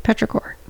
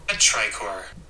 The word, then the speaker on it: petrichor: Petrichor. – Pronounce it. – Petrichor.